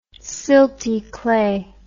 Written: silty clay

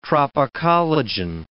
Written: tropocollagen

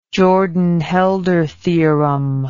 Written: jordan holder theorem

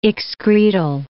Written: excretal

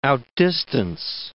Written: outdistance

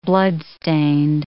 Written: bloodstained

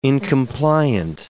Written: incompliant

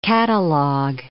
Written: catalogue

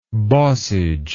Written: bossage

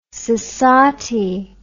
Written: sassatie

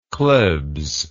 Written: Klebs

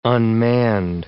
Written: unmanned

